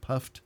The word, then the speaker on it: Puft: He said it was the Angelus Puffed. – And it's spelled P-U-F-T. – Yeah. – Not E-D. S-T-A or S-T-A-Y? Puffed. – Legs up. S-T-A-Y.